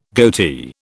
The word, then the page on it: goatee: British English